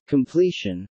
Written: completion

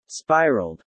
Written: spiraled

